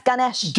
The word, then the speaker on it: Ganesh: That's Ganesh.